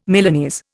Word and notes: Milanese: Indian

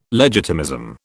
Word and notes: legitimism: British English

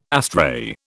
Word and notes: astray: British English

